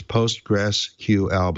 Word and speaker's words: PostgreSQL: That's not how it's pronounced. It's not how it's ever been pronounced. You know, there was a product out of IBM called SQL for a while, but it was spelled SQL, the whole word. But SQL is SQL, so it's PostgreSQL.